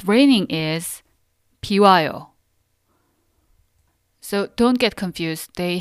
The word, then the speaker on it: 비와요: 비와요. 비와요. 비와요.